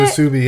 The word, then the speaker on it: musubi: Ooh, is that how you pronounce it? – Musubi, yeah.